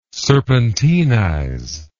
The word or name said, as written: serpentinize